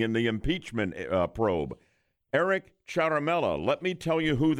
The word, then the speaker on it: Ciaramella: is a man by the name of Eric Ciaramella. C-I-A-R-A-M-E-L-L-A, we're told pronounced Ciaramella.